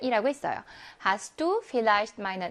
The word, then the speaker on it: Vielleicht: 자, 그러면 Vielleicht V는 F 발음을 냅니다.